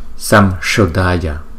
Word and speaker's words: Samsodaya: Cho nên là chúng ta thấy là chẳng hạn như là Samsodaya.